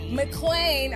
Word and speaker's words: MacLean: Am I saying it right? – MacLean. – I'm so sorry.